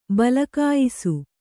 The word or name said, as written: ಬಲಕಾಯಿಸು